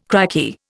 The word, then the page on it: crikey: Indian